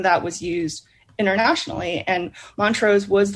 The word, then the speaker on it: Montrose: Is it Montrose Chemical Corporation? Is that how you say it? – Yeah, Montrose.